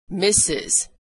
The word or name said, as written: Mrs.